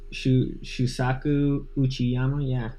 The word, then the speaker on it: Shusaku Uchiyama: Suzaku Ukiyama. You agree with that pronunciation? Shuzaku? – Shusaku Uchiyama? Yeah, that's good.